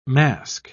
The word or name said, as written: mask